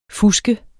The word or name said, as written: fuske